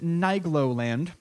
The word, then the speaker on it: Nigloland: This next story comes to us from France, where the theme park, and the name of this theme park is, I'm going to pronounce it Nigloland.